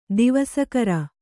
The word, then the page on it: ದಿವಸಕರ: divaskara